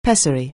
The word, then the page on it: pessary: anglais